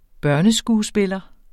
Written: BARNESKUESPILLEREN

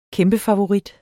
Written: kæmpefavorit

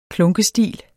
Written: klunkestil

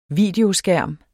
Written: videoskærm

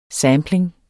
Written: sampling

